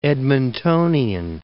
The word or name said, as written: Edmontonian